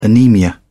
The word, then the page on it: anaemia: British English